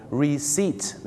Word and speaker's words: receipt: receipt ไม่ใช่ receipt นะฮะก็จะออกเสียงว่า receipt นะครับผม receipt